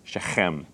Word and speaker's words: Shechem: It's Shechem, okay? That's literally how it's said.